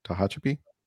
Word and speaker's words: Tehachapi: Um, some other people, Ta- Taha, I'm going to butcher this name, Taha Chapi, California, Tehachapi, California stayed open and mass free as well.